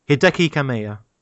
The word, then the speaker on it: Hideki Kamiya: Hideki Kamiya. God, I really hope I pronounced that guy's name right. – Hideki Kamiya. Kamiya. he. – How'd you say it? Hideki Kamiya.